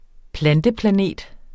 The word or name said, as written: planteplanet